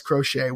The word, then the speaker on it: Crochet: I am joined by a man whose name I did not know how to pronounce about 10 minutes ago, Wes Crochet.